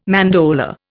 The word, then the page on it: mandorla: Indian